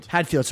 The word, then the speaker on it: Hadfield: Chris Haddonfeld? – Hadfield, sorry.